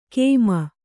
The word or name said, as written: ಕೇಮ